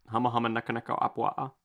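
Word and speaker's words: Hama Hama Naka Naka Apua (humuhumunukunukuāpuaʻa): I think that's how you say it. Hama Hama Naka Naka Apua. – That is exactly what I was going to say.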